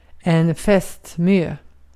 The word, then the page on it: fästmö: /ˈfɛst.ˌmøː/